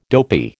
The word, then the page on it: dopy: American English